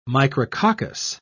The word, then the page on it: micrococcus: British English